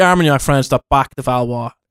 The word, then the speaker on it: Armagnac: Is am I pronouncing that right? – Yeah, Armagnac.